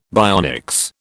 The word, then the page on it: bionics: British English